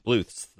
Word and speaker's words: Bluth: So how do you pronounce his last name? Lucille Bluth. On Arrested Development. – Bluth.